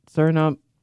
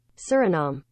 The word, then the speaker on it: Suriname: That's how you pronounce it, Suriname. Right, Google? – Suriname.